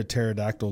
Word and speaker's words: pterodactyl: Because the P is silent. Okay, I got a different one. – Spell it. – P P-T-E-R-O-D-A-C-T-Y-L.